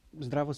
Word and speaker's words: Zdravo: ¿Es Dravo? No. ¿Cómo se dice hola en esloveno? – Zdravo, sí.